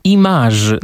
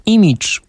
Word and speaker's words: image: Autorzy i autorki słownika zalecają, by to słowo wymawiać jako image, natomiast image uznają za wymowę pretensjonalną. Dziś jednak to właśnie wymowa zbliżona do angielskiego oryginału jest powszechna, a wersja francuska brzmi sztucznie i nienaturalnie.